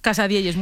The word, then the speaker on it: casadielles: Y nos trajo una cosa que se llama Casadielles, ¿no? ¿Lo digo bien? – Casadielles, muy bien dicho.